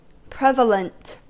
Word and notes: prevalent: American English

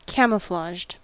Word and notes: camouflaged: American English

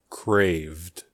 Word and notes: craved: American English

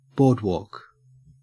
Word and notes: boardwalk: Australian English

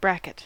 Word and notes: bracket: American English

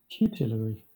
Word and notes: tutelary: British English

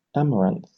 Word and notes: amaranth: British English

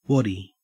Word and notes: waddy: Australian English

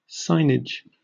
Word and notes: signage: British English

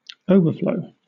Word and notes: overflow: British English

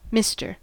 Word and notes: mister: American English

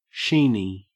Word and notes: sheeny: Australian English

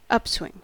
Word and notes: upswing: American English

